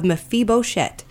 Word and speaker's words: Mephibosheth: And the story you've brought forward today is, I'm going to try to sound this out, is the story of Mephibosheth. – Yes. – Found in the Old Testament. – Okay, yes, and I think you have said it correctly.